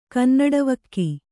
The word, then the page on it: ಕನ್ನಡವಕ್ಕಿ: kannaḍavakki